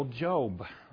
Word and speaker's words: Job: We pronounce it with a long O, Job.